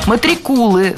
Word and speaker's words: матрикулы: Были матрикулы, то есть эти списки студентов. – Матрикулы – слово хорошее, редкое, интересное, но произнесено оно неверно. Правильно – матрикулы. Ударение ставим на второй слог.